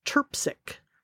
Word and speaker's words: Trpcic: It all comes together with costumes and the costume designer, which is Shauna, and her last name is pronounced Terpsic. So it is spelled T R P C I C but luckily, I found a great website that looks very interesting about costumes, and it's called My Disguises.